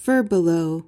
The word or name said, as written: furbelow